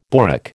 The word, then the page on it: borak: American English